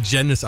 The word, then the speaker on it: Janiz: Das Einfachste, glaube ich, tatsächlich googeln und im Kopf behalten, dass wir Janice ausgesprochen werden. Und dann findet ihr alles, was über uns zu wissen gibt. – Ja, nur Aussprache bei Google immer schwierig. Schreibweise ist wichtiger. J-A-N-I-Z.